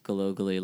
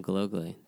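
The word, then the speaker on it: Gallogly: That's Glogally like a crazy last name. Just figure it out. And that's my Instagram too, at Liam Glogally.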